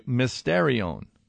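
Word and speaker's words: mysterion (μυστήριον): Now, the Greek word, by the way, I'll pronounce it for you, mysterion.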